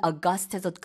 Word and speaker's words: august: 그래서 이 8월, August랑 스펠링이 같은데 발음은 August에서 그 뒤에 부분에 악센트가 들어가고요.